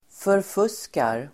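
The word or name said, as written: förfuskar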